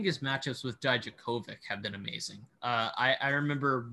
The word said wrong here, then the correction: Djokovic: one year I was watching at a – oh, did I just butcher – Novak Djokovic. – Oh, no, I just butchered Djokovic's name. Did I just really say Dijakovic?